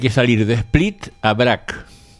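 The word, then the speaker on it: Brač: Busca ahí la de Brac, se escribe así como suena, Brac.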